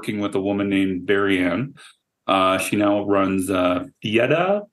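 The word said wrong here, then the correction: Fiadh: It's just Fia. Because it's Gaelic. – Yeah, I don't know how the hell you pronounce it, but okay. – Yeah, because the DH is silent.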